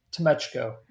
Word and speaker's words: Tomechko: Tomechko. – Say it again. – Tomechko.